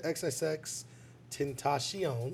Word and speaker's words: XXXTentacion: But I mean, so RIP to XXXTentacion.